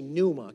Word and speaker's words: pneuma: The, the word spirit in Greek is actually pneuma. Can y'all say pneuma? Okay, it's It's it's smelled. It's spelled like uh, like uh, what's that word? Um, like pneumonia, P N E U M A.